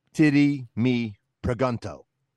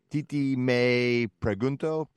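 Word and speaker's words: Tití Me Preguntó: So in Ohio, they would say, titty me pregunto. – Right. – But that's not how it's pronounced, right? Because it's Spanish. So it's more like something like, titi me pregunto.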